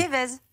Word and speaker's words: Fievez: Comment ça se prononce Allez-y, dites-le vous. – Fievès. Fievez, Monsieur Fievès, euh, Céline.